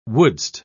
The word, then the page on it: wouldst: British English